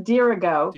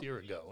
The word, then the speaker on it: Dirigo: It's pronounced Dirigo. – Dirigo. Okay. – It's Latin for I lead.